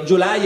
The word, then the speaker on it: Gyulay: O meglio, Gyulay è il modo in cui lo pronunciano gli italiani, in ungherese non pretenderete che lo pronunci in ungherese, ma comunque gli italiani lo chiamano Gyulay